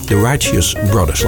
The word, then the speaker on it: The Righteous Brothers: The Righteous Brothers, laat ik het goed uitspreken.